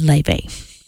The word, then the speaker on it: Laufey: Laufey, Leve. I looked it up after I said it wrong, so I apologize to those people who know better than I do.